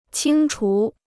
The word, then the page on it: 清除: qīng chú